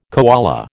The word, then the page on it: koala: American English